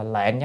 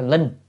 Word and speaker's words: lệnh: E1 là Phật ra lệnh hoặc là bảo á, chứ là đọc là lệnh chứ không phải linh. À, có lúc thì đọc linh, có lúc là lệnh, nhưng mà đời này phải đọc lệnh.